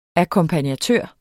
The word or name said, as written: akkompagnatør